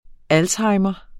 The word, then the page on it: alzheimers: ˈalshɑjmʌs